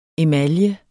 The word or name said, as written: emalje